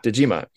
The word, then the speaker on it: Dejima: the Dutch trading post called Dejima. I don't know how you pronounce it. – Dejima.